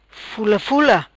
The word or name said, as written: Fula Fula